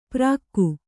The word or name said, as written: ಪ್ರಾಕ್ಕು